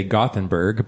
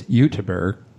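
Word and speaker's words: Göteborg: Now, Helen Toursteyn, you may already know, she writes a series of mysteries starring Inspector Ibrahim Hus, based out of, well, we would say Gothenburg, but what I discovered, the Swedes pronounce it as Uteberg.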